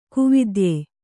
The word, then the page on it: ಕುವಿದ್ಯೆ: kuvidye